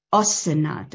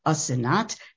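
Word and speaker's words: Asenath: Asenat, and that is the pronunciation. It is Asenat because it's an Egyptian name, and Nat is the name of a god, and the S sound is belongs to. She belongs to the god.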